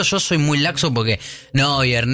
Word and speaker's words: Neuer: Me acabo de dar cuenta que es el arquero que yo pronuncié muy mal. – Neuer. – Y yo dije Neuer. Sí. Y es Neuer.